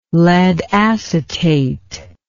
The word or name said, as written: lead acetate